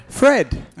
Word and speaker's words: Phred: Fred. – All right. – Bofcon. – But it's spelled P-H-R-E-D. – Yeah. Fred. Bofcon. It is Fred here.